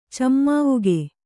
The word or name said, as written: ಚೆಮ್ಮಾವುಗೆ